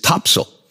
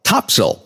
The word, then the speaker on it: Topsail: And if you go to the area and you spend enough time there, you will very quickly find out that the way to pronounce it is Topsail. So it's a real quick T-O-P sound, and the second syllable is very quick and more like a small S-I-L. Topsail.